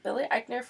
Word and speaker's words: Eichner: Yeah, so they're doing a summer camp thing, and they're doing a bunch of games, and Billy Eichner, that's how you say his name? Yeah. – I love it. Billy Eichner from Billy on the Street.